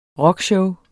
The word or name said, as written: rockshow